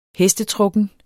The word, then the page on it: hestetrukken: [ -ˌtʁɔgən ]